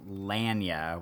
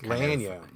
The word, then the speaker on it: Lanya: So her name is actually pronounced Lanya, which is Lanya. – Yeah, Lanya. It's Lania, and her last name is spelled Knight, which is like a knight in shining armor. Lanya Knight.